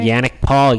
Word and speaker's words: Yannick Paul: The next group is Adam Scott, Max Homa, and Yannick Paul. Am I saying that right? – Yannick Paul, yeah.